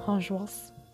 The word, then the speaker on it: angoisse: Angoisse. I don't know how to say it even. – Angoisse.